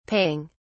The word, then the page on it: paying: British English